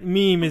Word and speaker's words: meme: But I also have to say, I'm going to skip ahead, and I saw the next question for least favorite, and how do you say this word? Meme? Is that it? – You got it right. Meme is the...